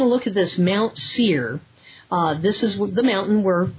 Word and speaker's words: Seir: Okay, it's actually Mount Seir. – Mm-hmm. – Okay, that's spelled S-E-I-R. And so whenever you see Seir, S-E-I-R, is going to be speaking about Esau's basically kingdom that he had and that he built and the people of that.